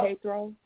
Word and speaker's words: Jethro: Jethro? – Jethro.